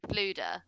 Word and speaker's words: Fflewddur: To be fair, I know that you're struggling with his name, Fluda, but it is spelled FFLEWDDUR. – Yeah. – So what chance did you have? – It's a Welsh name.